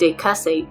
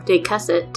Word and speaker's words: decussate: In botany, the adjective which is pronounced decussate, or decussate, describes leaves that are arranged along the stem in pairs, with each pair at right angles to the pair above or below.